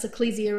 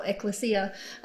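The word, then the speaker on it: ekklesia: So when we're talking about, depending on where you grew up as to how you pronounce ecclesia or ecclesia, I say ecclesia, you say ecclesia, whatever, potato.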